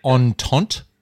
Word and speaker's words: Entente: So mine, and you'll have to pronounce this for me because you're the prophet, but Entente. – You don't pronounce the last word if it's French.